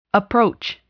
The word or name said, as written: approach